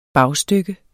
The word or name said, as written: bagstykke